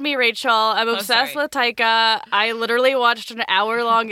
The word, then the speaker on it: Taika: How'd you say Taika just then? – Taika. – Taika? – I think it's just Taika Waititi.